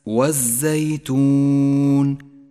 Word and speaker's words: والزيتون: لاحظ اللام الشمسية في كلمة والزيتون والرمان، لونت اللام في هاتين الكلمتين بالرمادي لأنها لا تلفظ، فهي لام شمسية تكتب ولا تلفظ.